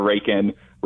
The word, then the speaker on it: Raikin: Um, you know, my, uh, you know, Alexander Raikin. Raikin is spelled R A I K I N.